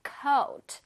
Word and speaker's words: coat: 코트를 해서 좀 길게 발음해 주세요. coat.